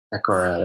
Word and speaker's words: Decorah: Decorah, that's right, Iowa. And it's like Deborah with the C.